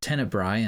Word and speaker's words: Tenebrae: The standard, quote-unquote, English language title used today, Tenebrae, with the A-E on the end, is actually a Latin word, pronounced tenebrae in Latin, meaning darkness.